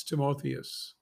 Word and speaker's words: Timotheus: Verse 20 Revere mighty one, that's Timotheus phonetically.